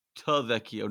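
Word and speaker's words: Tavecchio: We have hyped up Giorgio Trevecchio. – Trevecchio. No R. Just like Italian.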